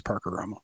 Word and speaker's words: Parker Romo: Well, you should learn to say his name as he prefers it, which is Parker Romo. – I know it is. I know.